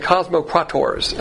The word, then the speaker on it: cosmocrators: These are called the cosmocrators, and I'm going to change that to a K, because in the Greek you can have K or C, but I like K. It's pronounced the same way with a hard K.